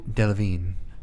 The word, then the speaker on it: Delevingne: Delevingne. It's one of those... several of the letters don't actually get pronounced.